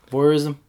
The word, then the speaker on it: voyeurism: It's a bit like, it's a bit voyeurism, isn't it? Is that how you pronounce it? Voyeurism. – Yeah, voyeurism.